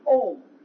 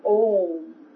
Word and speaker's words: Aum: And the original word is Om. Now, we mispronounce that by calling it Amen or Amen. So the original one is A-U-M, and it's Om.